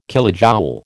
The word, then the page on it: kilojoule: American English